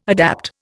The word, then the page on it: adapt: Indian